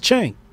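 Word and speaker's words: Cenk: Sank. Sank, yeah? Sank. – I think it's pronounced Chank.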